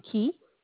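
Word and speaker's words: Ke: K-E, Key. – K-E-T? – It's Key. It's spelled K-E, pronounced Key, sir.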